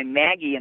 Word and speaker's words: Maggi: It's Notes from Down the Rabbit Hole, and it's by Maggie, and that's spelled M-A-G-G-I. It's a play on the Magi.